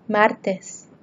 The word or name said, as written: martes